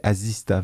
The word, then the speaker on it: Asista: C'est A-S-I-S-T. C'est Asista, vraiment ça se prononce assistant.